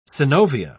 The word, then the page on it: synovia: British English